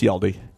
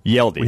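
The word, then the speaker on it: Hjalte: To the point where we needed to figure out, it's spelled Hajalti, but it's Yeldy. – Yeldy.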